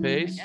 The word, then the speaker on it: Pase: So, Rain Pace, P-A-S-E, and I'm saying that right, Pace? – Yes.